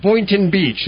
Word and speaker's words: Boynton Beach: Boynton Beach. Is that pronounced right? – That's correct. Good.